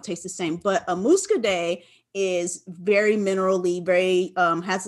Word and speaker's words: Muscadet: If you ever had a Muscadet or Muscadet, not to be mistaken with Moscato, but something like a Muscadet. – Is that how you pronounce it? I think I've seen the spelling. I never knew. – Yeah. Got so Muscadet.